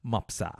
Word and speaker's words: Mapusa: And this message comes in from Susan Dice Mapusa. Oh, how they say it right here. Mopsa.